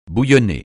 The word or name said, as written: bouillonner